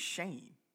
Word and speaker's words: Schoen: I kept calling him Shewin. His name is Shane, but it's spelled like Shewin know. Joe Schoen.